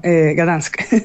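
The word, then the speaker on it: Gdańsk: Yo pensé, entonces yo les comento como, yo vivía en Gdansk. Y como que no entienden, a lo mejor lo pronuncio muy mal. Y luego digo Danzig. Y es como, no, Gdansk. – Ah, claro. – Gdansk, lo que dicen.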